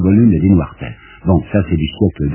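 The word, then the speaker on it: Desnoiresterres: Des Noires Terres. D-E-S, Des Noires Terres.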